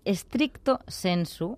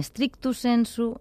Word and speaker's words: stricto sensu: Mira, stricto sensu, en sentit estricte, que no és ni stricto sensu ni stricto sensu, sinó stricto sensu.